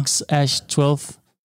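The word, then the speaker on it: X Æ A-12: hvor han så siger X, ligesom i bare bogstavet X, men så siger han, at det der A, som de så kalder vores A, altså AE, det udtaler han så som Ash, og så A12. Så det er sådan her X-Ash-12.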